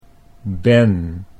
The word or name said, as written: ben